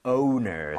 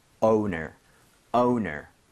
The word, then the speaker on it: owner: 그래서 발음할 때 워가 아니라 얘는 이렇게 되기 때문에 이 모양을 무조건 모아 주셔야 됩니다. – Yes. – 이것도 한번 해볼게요, 여러분들. Owner. Owner.